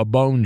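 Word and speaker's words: Bohn: I've seen every version of a bone joke. Everyone you can imagine. – Is it spelled like a bone? – It's B-O-H-N, but it's pronounced bone.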